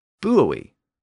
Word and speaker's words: buoy: So that gives us something like buoy, which is very close to the modern American pronunciation of buoy. The important thing about this triphthong, though, is that it means that the U is pronounced as a vowel, U, and not as a consonant W sound, W, as Walker and that American school book had suggested.